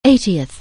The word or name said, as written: eightieth